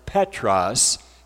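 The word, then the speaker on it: Petros (Πέτρος): in Greek, his name would be pronounced Petros, and Petros has the meaning of an isolated stone.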